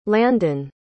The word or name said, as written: landyn